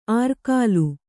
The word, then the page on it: ಆರ್ಕಾಲು: ārkālu